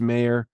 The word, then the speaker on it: Meyer: I've heard Meyer be pronounced Mayer. I just say Meyer, but yeah, Mayer, we can say whatever we want there.